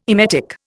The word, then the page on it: emetic: Indian